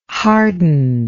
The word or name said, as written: hardened